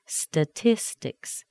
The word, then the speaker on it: statistics: Statistics, the T is before a weak syllable. Sounds like a D. Statistics, statistics.